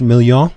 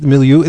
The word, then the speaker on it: milieu: This is Corbin's in French million. Is that how you say it? This is milieu.